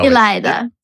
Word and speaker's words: Ilayda: You should say your name so that we can all just know Ilayda.